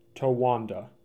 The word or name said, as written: Towanda